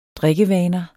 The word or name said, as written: drikkevaner